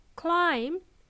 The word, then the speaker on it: climb: Okay, by the way, the verb climb has a silent B, B for Bob. It's spelled as C-L-I-M-B, B for Bob, right? We simply pronounce it as climb, not climb or climb, okay?